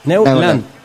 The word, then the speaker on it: Neulan: Con un gol agónico de Gustavo Bou, metió a Neu... Bueno, son... – Sí, usted léalo como se escribe. Neu Neulan. – Neulan. Sí.